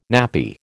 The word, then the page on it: nappy: American English